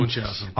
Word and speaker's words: Munchausen: Baron, I can never pronounce it, whether it's Munchausen or Munchausen. – Munchausen.